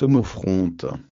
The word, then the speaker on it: homophronthe: Cette expression d'un seul esprit est un mot grec qui s'écrit H-O-M-O-P-H-R-O-N-T-H-E. Encore une fois, c'est H-O-M-O-P-H-R-O-N-T-H-E, et il se prononce homophronte.